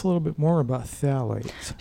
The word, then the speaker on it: phthalates: but these are endocrine-disrupting chemicals called phthalates.